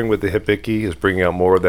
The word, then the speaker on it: Hibiki: Hibiki.